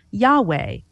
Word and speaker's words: Yahweh: The scholarly consensus is it should be pronounced Yahweh.